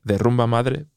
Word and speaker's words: The Rumba Madre: Why don't we add like T-H-E, like the Rolling Stones, that kind of the who, that whatever, but we pronounce it like in Spanish, so de. So it's like the puta madre, the rumba madre, but it's written the or the rumba madre.